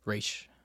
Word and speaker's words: Ra's: Ra's al Ghul, Raish, Raish al Ghul. But they say Raz. I don't know why, but it's actually pronounced Raish.